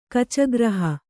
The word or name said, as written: ಕಚಗ್ರಹ